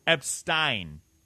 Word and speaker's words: Epstein: And it's Epstein, I believe. Epstein is how you pronounce it.